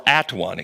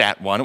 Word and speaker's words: atone: But when you make them at one, it was not pronounced at one. It was pronounced atone, because that's the Old English pronunciation, so that when you're all one, you're not all by yourself.